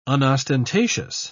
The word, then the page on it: unostentatious: British English